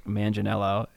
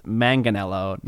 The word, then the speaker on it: Manganiello: Okay, step one to meeting Mark Manganiello is that his name is Joe, not Mark, and his last name is pronounced Manganiello, not Manganiello. So you got to get that right or you're going to piss him off a lot.